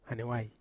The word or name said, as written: hanewai